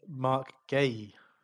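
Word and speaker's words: Guéhi: Mitchell and Gihai, how do you pronounce that? Gahi? How do you pronounce his name? – He pronounces it Mark Gay. – Gay. Okay, well, him and Mitchell seem to have an instant understanding together.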